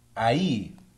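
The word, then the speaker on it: ají: And this is called ahi. – Ahi. – Ahi. – Ahi. – The J is silent. – Ahi. It sounds like a H. It's like Jose. – Ahi. – Yeah, ahi. Because A, it's A-J-I. That's how you spell ahi.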